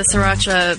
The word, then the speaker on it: Sriracha: Yeah, he's created the Sriracha biosphere actually for himself. – Oh, I said it wrong. I always say it wrong. I always say Shiraka, but I guess there's not even an H in there.